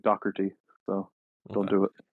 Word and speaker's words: Doherty: Well, here's the mad thing about how English people pronounce it, is that when you spell it in Irish, there is a CH. That's the mindfuck. But over here, it's just Doherty. You say it as you see it. There's no hidden C.